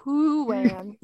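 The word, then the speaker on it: Heughan: You know, he's that fabulously cute Scottish actor, Sam Hoohen. Okay. That's not how it's spelled, but that's how it's pronounced.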